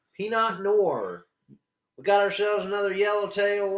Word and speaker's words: Pinot Noir: I don't even know if you're supposed to pronounce the R at the end or like Pinot Noir. – Pinot Noir.